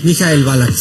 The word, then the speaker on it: Michael Ballack: ¿Cómo se dice bien? ¿Michael Ballack? – Michael Ballack, sí.